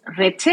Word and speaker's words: Reche: Okay, in Spanish it's Reche. I know it's for Reche, yeah.